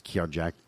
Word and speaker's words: Kirjak: K-I-R-J-A-K? Can we agree on Kirjak? No, his last name wasn't Mihoff. – No, spell it again. – K-I-R-J-A-K. Kirjak? – Yeah, I'll agree with it.